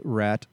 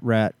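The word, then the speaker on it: Ratt: you can follow me at Matt Rat Comedy.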